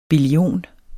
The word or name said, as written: billion